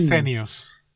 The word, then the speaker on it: Esenios: Y Casey dijo que María y José no eran dos personas así cualquiera, que eran parte del grupo, los, ¿cómo se dice? Essenes, Esenios.